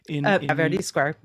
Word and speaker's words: Verdi Square: You write about this experience in, is it pronounced Verdi Square? – Verdi Square. – Mm-hmm. Verdi Square, yeah.